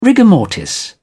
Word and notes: rigor mortis: British English

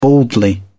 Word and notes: baldly: British English

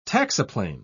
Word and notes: taxiplane: British English

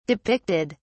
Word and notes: depicted: British English

